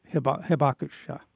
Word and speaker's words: Hibakusha: We apologize to the Hibakusha. I don't know if I'm saying that right. The survivors. – Hibakusha.